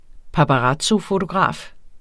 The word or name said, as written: paparazzofotograf